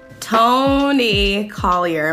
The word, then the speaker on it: Toni Collier: Toni Collier.